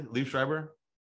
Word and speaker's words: Liev Schreiber: And then Deputy Stu Wargle, who Liev Schreiber and. Is that, am I pronouncing that correctly? Liev Schreiber? – Yeah, you got it.